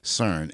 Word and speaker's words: CERN: So, it's pronounced CERN and it's spelled S-E-R-N. – C-E-R-N. Come on, motherfucker. – C-E-R-N.